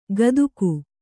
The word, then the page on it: ಕದುಕು: Kannada